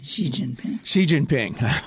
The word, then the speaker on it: Xi Jinping: Jing Xiaoping, right? I probably blew the pronunciation. – Xi Jinping.